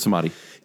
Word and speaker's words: Samadhi: I can never say that right. – I was like, Secret Samurai. – Like, it can't be Samurai. That's not it.